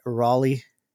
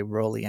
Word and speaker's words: Rolly: I used to call it Raleigh, but I heard someone else say Rolly, and he said it Rolly, so I, I, I learned how to say his name.